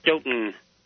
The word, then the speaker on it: Stoughton: Just no offense, but up here, everybody refers to the town she lived in as Stoughton. Stoughton. – Oh, did I say Stoughton? – Yes, you did, but that's okay because that's the way it's spelled. We have a lot of Norwegian ancestry people up here, and, well, to be honest, well, you know here, when they talk about the town, it's Stoughton there.